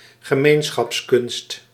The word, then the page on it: gemeenschapskunst: ge·meen·schaps·kunst